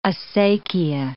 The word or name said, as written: acequia